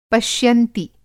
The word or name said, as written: पश्यंती